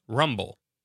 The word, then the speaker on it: RMBL: Gothic is home to the Rocky Mountain Biological Laboratory, RMBL, pronounced Rumble.